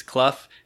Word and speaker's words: Clough: especially when your last name is Clough and it's pronounced that way, and your parents didn't think, hey, maybe we should spell Elliot with two L's and two T's.